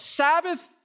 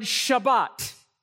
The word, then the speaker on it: Shabbat: Sabbath is the English way of pronouncing an ancient Hebrew word. It's the word Shabbat. Shabbat.